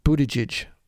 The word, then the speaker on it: Buttigieg: His name is Pete Buttigieg,